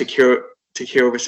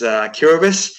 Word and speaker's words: Kiribati: Tony, I noticed you did announce that as Kiribati, but most people do, to be honest, when you see how it's spelt. But it's in the local language, it's Kiribati.